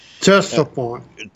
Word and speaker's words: Ctesiphon: and I don't know how to pronounce that, Ctesiphon. The C is silent.